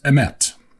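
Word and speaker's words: emet (אמת): The word is pronounced emet.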